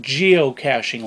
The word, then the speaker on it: geocaching: It's pronounced geocaching, like cashing a check.